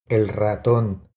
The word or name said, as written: El raton